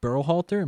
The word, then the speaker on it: Berhalter: Greg Berhalter.